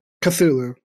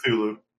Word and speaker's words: Cthulhu: How do you pronounce Cthulhu? – Cthulhu. – Cthulhu. – Good.